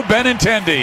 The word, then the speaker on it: Benintendi: Oh, what a play by Andrew Benintendi. – Got the name right.